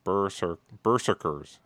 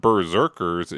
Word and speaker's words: berserkers: Berserkers. It's really one, like, it's not Berserkers, it's Berserkers. Right? It's like one sound at the beginning.